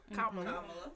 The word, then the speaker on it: Kamala: Vice President Kamala? – Kamala. – All right, Kamala. Harris. I said it correctly.